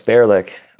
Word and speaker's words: Bearlick: You also brought up, like, is it Bearlick? Am I saying that right? – Yeah, it's Bearlick.